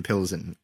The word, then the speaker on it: Pilsen: No, I did look up, and they are in fact located in Pilsen. I did get it. That's the English pronunciation. That's the Czech spelling right there.